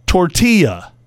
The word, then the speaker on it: tortilla: Pronouncing all the L's in the word tortilla. – Oh, like tortilla. – I can't stand it when people say tortilla.